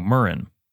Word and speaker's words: murrain: So the word moraine. Oh, Murren. That's the, I don't know if you can hear the, uh, the pronunciation lady from Google. Murren. Murren. A murren, M U R R A I N, is an infectious disease, especially babesiosis, affecting cattle or other animals, or archaic, meaning a plague, epidemic, or crop blight.